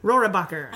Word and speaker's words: Rohrabacher: Rohrabacher.